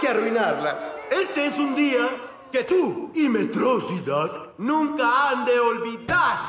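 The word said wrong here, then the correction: Metrociudad: Se pronuncia Metro Ciudad.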